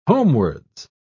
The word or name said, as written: homewards